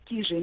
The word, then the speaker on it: Кижи: И действительно, прежде всего, да, важно правильно произносить, ударение ставить на первый слог. Слово Кижи имеет финно-угорское происхождение.